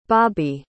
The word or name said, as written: bobbie